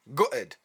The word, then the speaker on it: gutted: Up next, we've got the word gutted, or as I pronounce it, gutted.